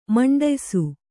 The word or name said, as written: ಮಂಡಯ್ಸು